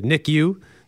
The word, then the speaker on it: NICU: She has been in the NICU. Did I say that right, Greg? – You did. – NICU at HSC for a few years now.